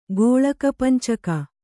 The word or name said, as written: ಗೋಳಕಪಂಚಕ